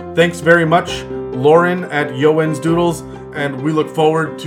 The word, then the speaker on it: Eeyowens: And you guys should check her out at Yowens Doodles. Again, that is E-E-Y-O-W-E-N-S. Doodles. It doesn't sound at all it's spelt like, but it is fantastic work.